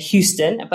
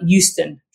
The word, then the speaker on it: Euston: The first train station that we're going to start with is Euston, which I am always mispronouncing and saying Houston, but it isn't. It's E-U-S-T-O-N, so Euston.